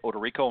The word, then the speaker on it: Odorico: You can go to my personal page, which is Ted Odorico, and my last name is spelled O-D-O-R-I-C-O, on Facebook, LinkedIn, and my Twitter handle is Ted and Buck CEO.